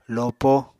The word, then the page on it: lopo: Apatani